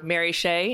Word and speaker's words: Mary Shea: My name is Mary, or Mary Shea in one syllable, as people like to say.